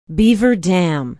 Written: beaver dam

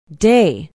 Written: Day

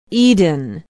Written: eden

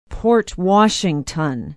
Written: port washington

